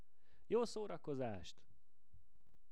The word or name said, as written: Jo szorakozast